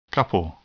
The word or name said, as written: couple